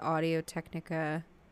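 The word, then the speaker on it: Audio-Technica: But my mic, I use the Audio Technica.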